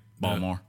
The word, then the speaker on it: Baltimore: Oh, you didn't say that right. – Baltimore. – There you go.